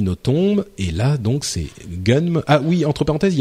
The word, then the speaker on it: Gunnm: Donc euh, voilà, ça s'appelle Gunm.